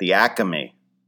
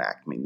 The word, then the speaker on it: Acme: So she also said there's a buy one, get one free soda at the Acme. When you're from Philly, you put that extra syllable in Acme. The Acme.